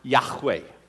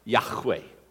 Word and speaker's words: Yahweh: But when they found out, when they really studied it, they found out that I am should be pronounced Yahweh. And I said that right, Yahweh.